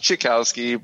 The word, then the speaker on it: Cichowski: It's pronounced Cichowski, but nobody cares.